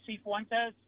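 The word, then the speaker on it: Cifuentes: Johnny C. Fuentes.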